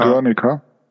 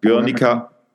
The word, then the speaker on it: Guernica: April 1937 deutsche Bomber Guernica, was spricht man das aus so Guernica? – Guernica. Guernica.